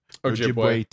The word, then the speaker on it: Ojibwe: So this is a quote from Basil Johnston, which is an Ojibwe, am I pronouncing that right? – Ojibwe teacher and scholar in Ontario, Canada.